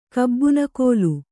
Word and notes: ಕಬ್ಬುನಗಲ್: kabbunagal

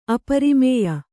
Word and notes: ಅಪರಿಮೇಯ: aparimēya; Kannada

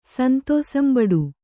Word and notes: ಸಂತೋಸಂಬಡು: kannada

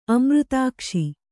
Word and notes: ಅಮೃತಾಕ್ಷಿ: kannada

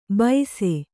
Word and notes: ಬೈಸೆ: Kannada

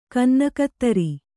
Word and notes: ಕನ್ನಗತ್ತರಿ: kannada

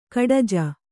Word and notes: ಗೂಢಜ: Kannada

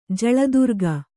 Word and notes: ಜಳದುರ್ಗ: kannada

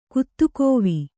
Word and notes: ಕತ್ತೆಗಿವಿ: kattegive

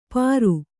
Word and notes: ಪರು: Kannada

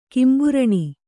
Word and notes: ಕಿಂಬುರಣಿ: Kannada

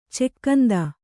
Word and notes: ಚಿಕ್ಕಂದು: cikkandu

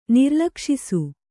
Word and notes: ನಿರ್ಲಕ್ಷಿಸು: Kannada; nirlakṣisu

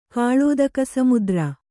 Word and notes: ಕಾಳೋದಕಸಮುದ್ರ: kannada

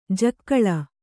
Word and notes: ಜಕ್ಕಳ: jakkaḷa

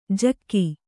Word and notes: ಝಗ: kannada

